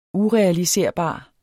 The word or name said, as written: urealiserbar